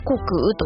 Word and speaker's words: 穀雨: 本日 4 月 19 日なのですが、穀らしいですよということで、穀っていうのでは穀物の穀に穀物の穀に雨と書いて穀雨と読みます。